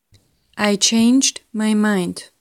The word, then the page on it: I changed my mind: slow